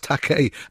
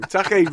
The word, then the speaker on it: Takei: He said, you keep pronouncing my name Takei, it's pronounced Takei, as in toupee. That's what he said to him. They haven't spoken for 20 years. – Must make dance with a song. Takei rhymes with toupee.